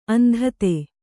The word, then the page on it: ಅಂಧತೆ: Kannada